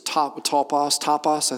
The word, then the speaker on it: topos: That word foothold, if you've read this in the book, is topos, I think is how it's pronounced in Greek, and that means literally ground.